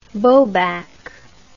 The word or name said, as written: bobac